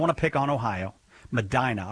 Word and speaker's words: Medina: If it's supposed to be pronounced Medina, I apologize. I never get those right. – Yeah, it's pronounced Medina. – Nah! – Everyone else calls it Medina. – Yeah, like a normal person, but not a person from Ohio.